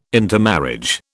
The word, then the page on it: intermarriage: British English